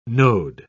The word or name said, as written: node